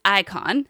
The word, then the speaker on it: Eikon: It looks like Akon, like the musician, but it's Icon. So it's spelled E-I-K-O-N.